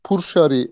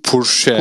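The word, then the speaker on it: Pourchaire: Ondan sonra e, o oturur mu oturmaz mı bilmiyorum ama e, bu po- nasıl okunuyor İskoç bu şey? Theo Purshari diye mi okunuyor bu çocuk? Purşe.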